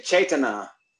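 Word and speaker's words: cetanā: No, no, no. Not not Vedana. Ch- Ch- Cetana, like uh, Chita, Chita, Cetana.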